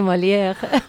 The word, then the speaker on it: Molière: de Molière.